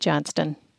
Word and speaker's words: Johnston: As my mother-in-law often introduced herself, or when providing her name for lists and lookups, that's Johnston with a T, she would say, as if the whole thing was her last name.